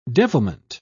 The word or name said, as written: devilment